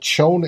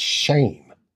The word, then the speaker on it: Schoen: And I also heard that it's not Schoen, it's Shane is the proper pronunciation.